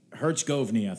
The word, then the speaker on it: Herzegovina: Herzegovina, thank you.